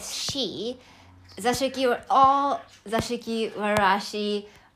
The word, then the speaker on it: Zashiki Warashi: Let me pronounce it right. Zashiki Warashi in Japanese, but maybe uh, people from English countries, I think they would say, uh, they would pronounce it like Zashiki Warashi or something.